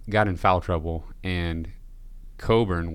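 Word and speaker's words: Kofi Cockburn: We said his name wrong, apparently, the entire last episode, but how in the world would you ever imagine that his name is pronounced Coburn? – I don't know.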